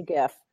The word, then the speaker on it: GIF: Founder of the GIF. – Yeah. – Said that it's a soft G.